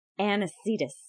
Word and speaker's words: Anicetus: And this is episode 13, Anicetus. – Unlucky. – Yes, he's not Anacletus, mind you. He is Anicetus.